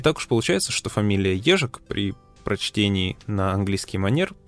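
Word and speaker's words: Ježek: Фамилия нашего сегодняшнего героя принято произносить в интернете как Джезак. Но это не совсем правильно, потому что фамилия это чешская, и произносится она правильно как «ежик».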